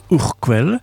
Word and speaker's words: Urquell: On écrit Urkel, mais on prononce Urquell, qui est brassé à Pilsen, dont le nom a donné le mot Pils.